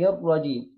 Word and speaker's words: الرجيم: النون المكسورة هنبدأ بعديها بحرف الراء المشدد، الألف واللام لا تنطق في كلمة الرجيم، فنقول إيه؟ من الشيطان الرجيم.